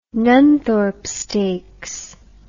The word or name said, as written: nunthorpe stakes